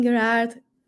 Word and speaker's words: Gairard: Yeah, so I'm as Catherine Gairard. Everywhere, so it's spelled C-A-T-H-E-R-I-N-E. And then my last name is G-A-I-R-A-R-D.